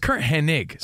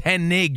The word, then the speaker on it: Curt Hennig: I didn't want to sound like uh, I'm Curt Hennig. I, am not Mr. Perfect. – It's Curt Hennig. – So know, you can't yeah. even say his name right. So you're far from perfect. It's Hennig.